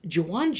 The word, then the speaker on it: Jauan: Yeah, one of the weirder ways to spell Juwan. He is back, yeah. – Is that how you say it? – No, that's how you say it. He just spells it J-A-U-A-N.